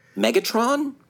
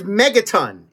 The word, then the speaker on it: Megaton: Megatron? – It is Megaton. It is spelled okay, Megaton.